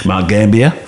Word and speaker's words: Gambier: Mount Gambier, because they always go up at the end.